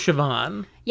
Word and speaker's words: Siobhan: Fun fact, we have a friend named Siobhan. – Yeah, which is how I know how to pronounce the name, S-I-O-B-H-A-N.